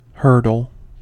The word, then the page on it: hurdle: American English